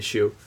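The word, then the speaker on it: Hsu: It's not Stephanie Sue, it's like Stephanie Shue. – Oh, there you go, Shue.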